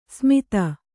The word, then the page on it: ಸ್ಮಿತ: smita